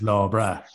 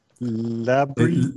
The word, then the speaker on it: La Brea: Yeah, and she did what you did and went, you know, the Irish for nice day, good day, like it's law bra. That is not how it's pronounced. They say it in the show, of course. – Yes, it's also quite a ways away from TG Cahir's production facilities. – It is, yeah. So it turns out Law Bra is actually in LA. – Who knew? – LA Bria. – La Brea, they pronounce it.